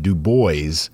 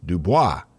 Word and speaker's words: Du Bois: Having previously lived in Maine and around a lot of French people, I pronounced the name Dubois. And someone laughed at me and, and said, We're not that fancy here. it's it's Du Bois.